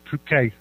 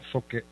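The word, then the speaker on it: Phuket: I just started letting you know that it's actually pronounced Pookay and not fuck it.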